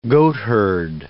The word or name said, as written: goatherd